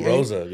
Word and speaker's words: Oroza: How you say his name? Oraza or Oroza? – Oroza, Hey, that fool.